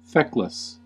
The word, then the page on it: feckless: American English; /ˈfɛkləs/; /ˈfɛklɪs/